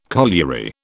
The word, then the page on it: colliery: British English